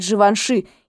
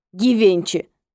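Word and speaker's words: Givenchy: А когда я показывала ей заказы от Живанши и случайно произнесла это имя так, как, казалось бы, оно и должно произноситься — Гивенчи, она даже не разозлилась по-настоящему. Последовало несколько уничтожающих взглядов и уничижительных комментариев, после чего мне было продемонстрировано правильное произношение, и жизнь вновь потекла относительно гладко.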